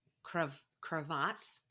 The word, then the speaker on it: cravat: It's a cravat. Is that how you say it? – Yeah, that feels right, I think.